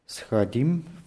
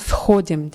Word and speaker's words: сходим: Давайте лучше сходим в кафе, а потом пойдем в клуб. – Ja. В клуб. В клуб, да. И давайте лучше сходим. Die Betonung auf O nicht vergessen.